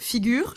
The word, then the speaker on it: Figures: Bonjour, aujourd'hui euh, on reçoit donc euh, le CEO de Figure, je le prononce comme il faut, je le dis pas à l'anglaise. On le dit à tu peux le dire à l'anglaise ou à la française, il n'y a aucun problème, les deux sont acceptés.